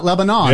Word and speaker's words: Lebanon: It's not Lebanon. – Yeah, Lebanon. – Lebanon. Kind of like Moscow. – Moscow. Everybody in Tennessee will come after you.